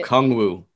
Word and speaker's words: Okongwu: Onyeka Ongagwu. Is that how you say Okungwu. – It? – I think O and then Kung Fu, but it's Kung Wu.